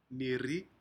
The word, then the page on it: niri: Aneityum